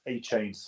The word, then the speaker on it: Achane: Moving on to Devin Achane with the Miami Dolphins. So this is one of the unsettled backfields in the NFL. And going into this draft, I didn't like his size. Nobody likes Achane's size.